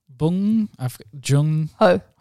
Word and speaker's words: Bong Joon Ho: Bong Joon Ho.